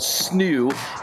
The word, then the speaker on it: SNHU: It's called SNU, in case you're not in the know. S-N-H-U gets pronounced SNU by the cool kids up there in New Hampshire.